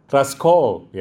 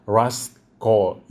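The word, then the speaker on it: Rust Cohle: Untuk season pertama, diceritain ada dua detektif, namanya Russ Cole, ya. Tulisannya Cole sih, tapi dibacanya Cole. Russ Cole.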